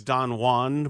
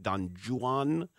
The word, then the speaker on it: Don Juan: I thought was Don Juan, but our teacher, our professor said it should actually be pronounced Don Juan because of the meter of the way the poem is set up.